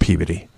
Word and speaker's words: Peabody: In Massachusetts, they pronounce it Peabody.